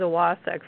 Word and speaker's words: Wacek: And my last name is pronounced Pariza Wasek, for anybody who is wondering.